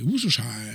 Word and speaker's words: Worcestershire: Oui, et ça se prononce juste Shire. C'est-à-dire comme le Worcestershire, qui est une région anglaise. – Oui, c'est un comté, et ça se dit Worcestershire.